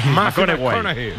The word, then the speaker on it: McConaughey: ¿Matthew McConaughey? – Sí, sí, sí. – Hay que ir corrigiendo la pronunciación. McConaughey. Matthew McConaughey.